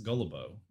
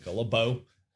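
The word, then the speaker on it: Gilbeau: Gullibo is, I think, how you say it. – Gullibo. His last name. Okay. – Not gullible, but Gullibo, B-E-A-U.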